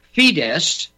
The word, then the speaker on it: Fidesz: Well, the reference there is the turn towards Fidesz, and again, I'll go to you, Tom, for the correct pronunciation of that Hungarian party, F-I-D-E-S-Z, Fidesz, led by the amazing Viktor Orban, who is he's the Donald Trump of Hungary.